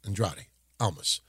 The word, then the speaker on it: Andrade Almas: So now Andrade Almas, that's how I pronounce his name, Andrade Almas, comes out to a nice pop, number 7.